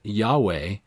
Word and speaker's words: Yahweh: Some Jews pronounce it Yahweh,